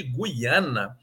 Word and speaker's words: Guiana: Agora, no caso de Guiana, nós temos um ditongo que deve ser pronunciado.